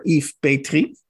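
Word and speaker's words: Yves Petry: Bij ons is eh, de Vlaamse schrijver Yves Petrie. Eh, als ik die klemtoon goed leg. Ehm, heel juist.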